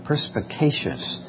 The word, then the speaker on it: perspicacious: The idea of enlightening the eyes is another fun word, and if I can ever remember how to say it right, it's perspicacious.